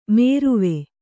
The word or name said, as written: ಮೇರುವೆ